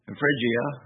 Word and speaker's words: Phrygia: And in verse 6, Paul said that they'd gone throughout Phrygia, actually is how it's pronounced, and the region of Galatia, and were forbidden of the Holy Ghost to preach the word in Asia.